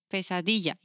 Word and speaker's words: Pesadilla: Pesadilla. It's one word. – Pesadilla? – Yes. – And it doesn't say the word night in it or what is no. – it? – No. – Pesadilla? – Pesadilla.